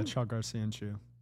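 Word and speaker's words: Çağlar Söyüncü: How do you pronounce his first name? Chalgar Soyanchu? – Yeah, Chalgar Soyanchu. – Bro, nailed it.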